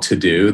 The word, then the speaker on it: TeuxDeux: There's one app called To-Do that's spelled T-E-U-X-D-E-U-X that we developed.